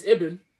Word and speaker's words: Ibn: I got his middle name right. It's Ibn.